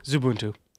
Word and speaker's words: Xubuntu: How do you say that? – What? – Xubuntu? – Zubuntu. – Oh, Zubuntu.